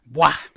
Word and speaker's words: buah: Now, if you want to look up these words, depending on the dictionary, there are slightly different spellings depending, but it's spelled B-U-A-H or sometimes just B-U-A, wah. Not to be confused with bua, which actually means owl.